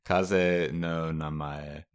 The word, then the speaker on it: Kaze no Namae: Kaze no Amane. How do you say it? – Kaze no Namae.